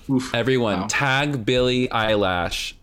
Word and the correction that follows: Eilish: Eilish, and tell me how bad I pronounced her last name.